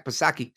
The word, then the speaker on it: Psaki: She's circling back like a real Jen Psaki, silent P, because she got nowhere to go.